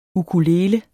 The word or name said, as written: ukulele